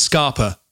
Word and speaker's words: Scapa: There's no R. – I know, but it's pronounced Scarpa for some reason. – Fair enough. Scottish. Normally, people north of Milton Keynes tell you off of putting an R when it's just an A.